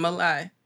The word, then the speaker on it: Malay: There was a brown person, Malay. Did I pronounce her name right? M-A-L-A-Y.